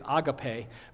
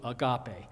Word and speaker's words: agape: It's actually pronounced in Greek agape, but we've gotten to a place where we've anglicized it to agape.